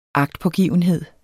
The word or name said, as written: agtpågivenhed